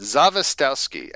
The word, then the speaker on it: Zawistowski: He left out the first S. It's Zawistowski. I'm sure if you're actually Polish, you would say Zawistowski, but my in-laws, it is a name by marriage. My in-laws say Zawistowski. They're from the Midwest, so they don't pronounce the W's as V's.